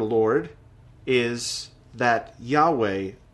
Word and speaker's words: Yahweh: We pronounce it Yahweh, although we don't exactly know how to pronounce it because there weren't any vowels in the Hebrew language, and the Hebrew people did not pronounce it.